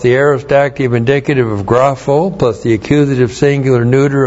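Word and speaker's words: grapho (γράφω): This time chi is a connective chi, correctly translated and grapho. G-R-A-P-H, long O.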